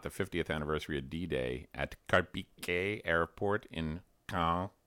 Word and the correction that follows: Caen: Caen in France.